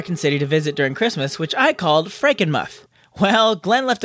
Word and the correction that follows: Frankenmuth: a comment saying, it's actually pronounced Frankenmuth, rhymes with tooth.